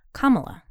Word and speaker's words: Kamala: And she pronounces it Kamala. Because I was calling right. her Kamala, too. – Kamala. – But then there's an ad with her and Biden. She goes, I'm Kamala Harris. And I was like, oh.